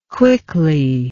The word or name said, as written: quickly